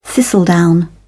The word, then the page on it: thistledown: British English